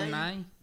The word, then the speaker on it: Bill Nye: Bill Nye.